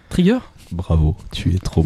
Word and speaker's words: Trigger: Trigger.